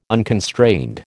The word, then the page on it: unconstrained: American English